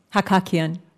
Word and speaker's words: Hakakian: Hakakian.